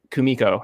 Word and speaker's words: Kumiko: So he does Kumiko, I think is how you pronounce it. – Yep.